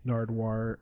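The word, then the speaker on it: Nardwuar: Yeah, it was, I've gone through, man, I went through a rabbit hole of just like Nardwar. That's right. That's how you say it.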